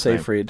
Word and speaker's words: Seyfried: I don't know how to say her last Seyfried.